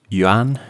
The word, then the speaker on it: Yuan: At the moment, they've got USDT, Euro T, Japanese Yen T, and the Chinese Yen has been added to it. – I believe it's pronounced Yuan.